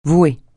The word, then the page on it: vouer: francais